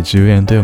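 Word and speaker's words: 従円: 従う円と書いて 10 円と読むん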